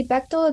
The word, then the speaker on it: Bachtel: Yeah, so my name is Heidi Bechtel. It's spelled H-E-I-D-I. Last name is B-A-C-H-T-E-L.